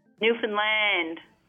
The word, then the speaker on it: Newfoundland: Newfoundland. Please pronounce it better for me, guys. – Newfoundland. – Okay, so it's heavy on the land part.